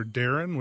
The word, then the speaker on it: Darin: If you do go to AMEC Home Loans and put in either Darin, which is my legal name, D-A-R-I-N.